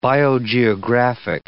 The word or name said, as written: biogeographic